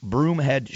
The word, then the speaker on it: Broomhead: You can always find me on Twitter at Broomhead Show. My last name is spelled just like it sounds,